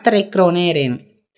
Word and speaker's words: Trekroneren: se Tranquilo, igual eso nos escriben y les decimos, pero se llama Trecroneren. T-R-E-K-R-O-N-E-R-E-N.